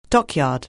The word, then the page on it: dockyard: anglais